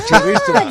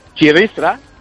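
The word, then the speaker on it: txirristra: Yo creo que lo más loco que has hecho, es tratar de enseñarnos a nosotros cómo se dice tobogán en euskera. – Ah, ¡qué bueno! Bueno, ¿puedes repetir? ¿Puedes repetirlo? – Chirristra. – Chirristra.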